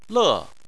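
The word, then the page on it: le: tone 4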